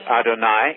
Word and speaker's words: Adonai: Rhymes with Adonai, easy Adonai. – To remember. Adonai rhymes with El Shaddai, and then there's Yahweh Nissi, my banner, and there's Yahweh Rophe, my healer, and so on.